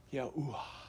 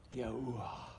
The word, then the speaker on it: Yahuwah: If you go to ancient Hebrew, it's pronounced this way. Yahuwah. Try saying it out loud. Yahuwah.